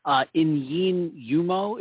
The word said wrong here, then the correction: Inyene Umoh: It's Inyene Umoh.